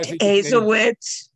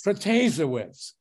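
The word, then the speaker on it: Protasiewicz: Azowitz, really? – Protasewicz.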